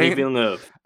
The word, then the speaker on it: Denis Villeneuve: Denis Villeneuve. Train. – Denis Villeneuve.